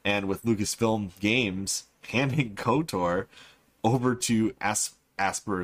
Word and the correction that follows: Aspyr: is what I keep pronouncing it as, but it's actually Aspyr Media.